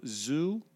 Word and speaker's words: Zhu: It's Z-H-U. – Is it Zhu? Zhu? – Zhu.